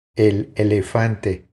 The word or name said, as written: El elefante